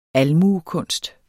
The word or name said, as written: almuekunst